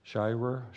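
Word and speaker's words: Shirer: Shire, I said it right the first time.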